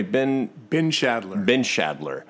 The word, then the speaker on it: Bindschadler: Bindschadler.